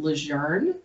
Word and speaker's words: LeJeune: It's a Cajun name. It actually translates into French into the young. But where I'm from, it's actually pronounced LeJune. And I gave up that fight around like fifth grade because there's no R in it.